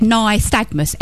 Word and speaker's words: nystagmus: And they said nystagmus, N-Y-S-T-A-G-M-U-S, nystagmus.